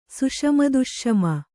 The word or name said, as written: ಸುಷಮದುಷ್ಷಮ